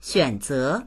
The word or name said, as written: xuan3ze2